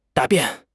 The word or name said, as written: 答辩